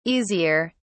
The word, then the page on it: easier: British English